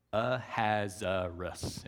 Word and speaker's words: Ahasuerus: Ahasuerus. If you read it in the text, there's no way that you would know that that's how it's pronounced. But that's how we pronounce it.